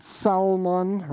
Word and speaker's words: Salmon: Nashon fathered Salmon, right? You have to say it like that because you don't want to sound like you're talking about fish.